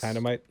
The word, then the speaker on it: Tynomite: where our user Tynomite—that's how you pronounce his name. – Yes. Yes. – Tynomite.